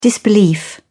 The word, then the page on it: disbelief: British English